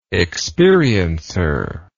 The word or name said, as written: experiencer